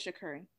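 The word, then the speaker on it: Sha'Carri: It's Shakari. Yeah, that's what I heard her say it. When she pronounced it on Wendy was talking, calling her name, she said, and it's Shakari.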